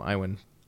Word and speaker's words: Iwan: I think that's how you say his name, Iwan.